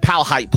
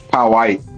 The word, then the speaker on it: Powhite: It depends on if you're from Virginia or not, for real, from Richmond, on how you pronounce this. I call it Powhite Parkway. But apparently, a lot of people say Powhite.